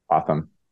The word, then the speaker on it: Pratham: Pratham.